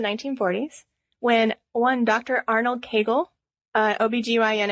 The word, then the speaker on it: Kegel: And we're going to call it Kegel. – Because it rhymes with bagel.